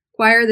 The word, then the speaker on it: choir: Choir, this is the last one. A choir is a group of people that sing together. And even though it's spelled C-H-O-I-R, it's pronounced choir.